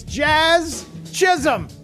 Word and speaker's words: Jazz Chisholm: To say his name, it's Jazz Chisholm.